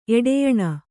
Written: ಎಡೆಯಣ

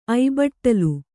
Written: ಐಬಟ್ಟಲು

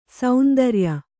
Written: ಸೇಂದ್ರಿಯ

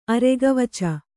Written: ಅರೆಗವಚ

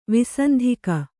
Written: ವಾಸಂತಿಕ

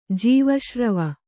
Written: ಜೀವಶ್ರವ

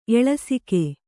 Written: ಎಳಸಿಕೆ

